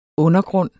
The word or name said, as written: undergrund